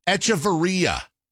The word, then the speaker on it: Echavarria: ECHAVARRIA. How would you pronounce that? – Echevarria? – Oh, I absolutely. They pronounce it Echevarria.